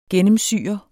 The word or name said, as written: GENNEMSYR